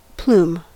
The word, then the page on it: plume: American English